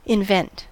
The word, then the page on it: invent: American English